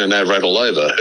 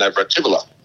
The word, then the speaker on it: Navratilova: So we go back to Martina Navratilova. Her name is actually pronounced Navratilova. But we say Navratilova because we're here in Australia.